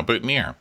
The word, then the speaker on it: boutonniere: But apparently, at least in America, it's pronounced "near." – Yeah, boutonniere.